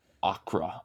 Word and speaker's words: Accra: And Accra. Okay. I, I am pronouncing that wrong, probably. – Yeah, Accra. that's fine. Accra.